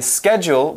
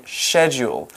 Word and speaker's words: schedule: And did you know it has two pronunciations too? – Really? – We can say schedule, we can also say schedule.